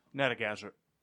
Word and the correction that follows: Narragansett: Natagazeret. – Narragansett.